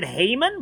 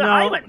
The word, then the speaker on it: Heiman: It's actually H-E-I. Like I would have said Haman, but I googled it and it turns out you pronounce it Hyman. – No, that's the correct spelling and pronunciation.